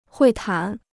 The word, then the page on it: 会谈: huì tán